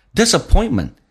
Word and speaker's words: disappointment: 所以后面你看像红色字体轻轻的微弱的点了一下 Whenever I face disappointment， disappointment 在美式发音当中那个 T 是不发音，因为后面是一个辅音，两个辅音碰在一起就用发后面一个，叫做 disappointment。